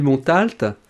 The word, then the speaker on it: Montalte: Moi, je dis Montalte. – Montalte? – Pour Montalte à la française,